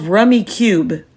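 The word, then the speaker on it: Rummikub: It's called Rummy Cube, and it's spelled Rummy Cub, and it's Rummy with an I. So okay. It's R-U-M-M-I-K-U-B.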